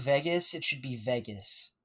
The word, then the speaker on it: Vegas: But Ben has this accent where he pronounces things with a V in it, like, incorrectly. So he's saying, like, Vegas or Vagas right now, and it should be Vegas.